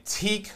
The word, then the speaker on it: Teque: So the Amiga version of Toobin was ported by Teek London. T-E-Q-U-E. – Yeah. – I think that's how you pronounce it.